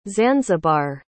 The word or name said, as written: zanzibar